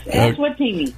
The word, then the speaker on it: Eswatini: And she'll give more information about the latest missionary journey to let me see if I say it right, Eswatini? – Yes, Eswatini.